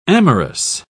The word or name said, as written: amorous